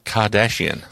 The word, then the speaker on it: Kardashian: Kardashian.